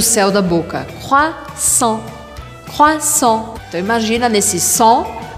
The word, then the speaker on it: croissant: Você provavelmente adora o croissant francês, mas você sabia que não se fala croissant, mas croissant.